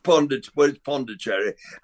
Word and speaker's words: Pondicherry: But one of my favourite parts of that whole series was when they took you, and I'm going to pronounce this wrong, is it Puducherry, to see a regional... – Pondicherry. I think the new pronunciation is probably Puducherry. Yes, I think it is. I'm afraid I stick to the old pronunciation because I can't get my tongue around the new one.